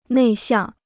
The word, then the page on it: 内向: nèi xiàng